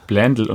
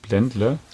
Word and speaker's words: Blendle: Ja, das heißt nämlich Blendl und nicht Blendle.